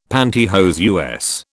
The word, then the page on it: panty hose: British English